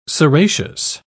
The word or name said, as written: ceraceous